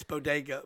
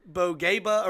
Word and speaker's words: bodega: Well, I know it's bodega, but bogeba or whatever she said. But she pronounced it wrong.